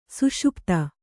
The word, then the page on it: ಸುಷುಪ್ತಿ: kannada